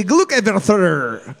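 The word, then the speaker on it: gluggaveður: And Takfir is spelled T-A-K-K-F-Y-R-I-R. Boards up. You're looking for B. Glukaivithr.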